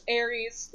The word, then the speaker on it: Ares: My name's Ares, like the Greek god.